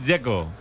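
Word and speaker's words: Džeko: Porque de Z, ¿cómo se pronuncia? – Dzeko. Bruno dice que es Dzeko. – Como tirando a J, Dzeko o algo así.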